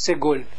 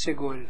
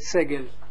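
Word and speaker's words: segol (סגול): Moeten we zo uitspreken als segol. Segol. Segol. Okay. Bij hem staat het segel. Maar goed, het is niet erg. Het is niet verkeerd. Maar liever moeten we segol uitspreken.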